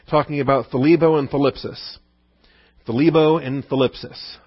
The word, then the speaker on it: thlibo: All right, thlebo is the verb, thlipsis is the noun. Thlebo, hard to pronounce, T-H-L. That's an awkward thl. We don't have any English words to start with. Thlebo. All right. T-H-L-I-B-O.